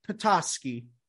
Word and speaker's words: Potosky: And today we have a special guest, Rebecca Potosky. Did I say it right? – You did. You did a great job.